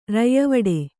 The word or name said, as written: ರಯ್ಯವಡೆ